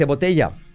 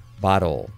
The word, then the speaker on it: bottle: ¿Cómo se dice botella? No es botel, es bottle. Bottle. Bottle. Como varón, pero sin N.